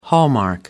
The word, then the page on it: hallmark: American English